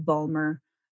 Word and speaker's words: Vollmer: And that is under my name, Amandha Vollmer, and Yum Naturals Emporium. So if you look up either my name, which is spelled with an H, A-M-A-N-D-H-A, Vollmer, V-O-L-L-M-E-R, and then Yum Naturals, you'll find me on there.